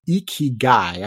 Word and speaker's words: ikigai: It's called ikigai. I think I pronounced that correctly. It's spelled I-K-I-G-A-I.